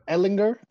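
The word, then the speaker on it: Ehlinger: Ellinger.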